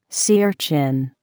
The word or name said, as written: sea urchin